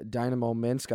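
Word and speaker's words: Dinamo Minsk: NHL player, recently played for Dynamo Minsk.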